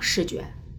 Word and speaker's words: 失觉: 这个“ 失觉” 这个词儿呢，发音 s h i g u e，是用我们陕西话来说叫失觉。